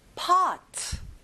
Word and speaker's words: pot: 오 소리가 아니라 어 소리예요. pot.